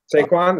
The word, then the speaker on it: Saquon: Saquon, is that how you say it? – Yeah.